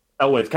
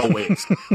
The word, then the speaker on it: Elwes: I think it's Elwes. – Elwes? – Elwes. Kind of like John Elway, but Elwes.